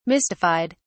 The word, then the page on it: mystified: British English